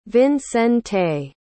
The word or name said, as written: vincente